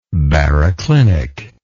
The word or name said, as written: baroclinic